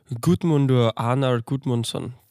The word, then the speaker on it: Guðmundur Arnar Guðmundsson: Øh, instruktøren er Gudmundur Arnar Gudmundsson, tror jeg. Mm-hmm. Og det er sådan, man udtaler hans navn.